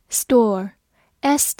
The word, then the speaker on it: store: Store，s t o r e，store，s 发 s。 t 放在 s 这个音之后，需要浊化成的。 o r 发 or 这个音，末尾的 e 不发音。